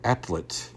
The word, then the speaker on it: Atlit: The find, which came just off the small Israeli town of, and let me make sure I pronounce it right, Atlit, A-T-L-I-T, was explored during the Smithsonian Channel short documentary, Stonehenge-like structures, been found all over the world.